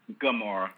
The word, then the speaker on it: Gamora: And she's the youngest to, how do you say her name? Is it Gamora or? – Gamora. Yeah.